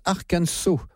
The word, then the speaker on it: Arkansas: Départ de cette émission, ça s'écrit Arkansas, mais ça se prononce Arkansas.